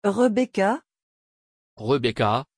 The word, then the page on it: rebecka: French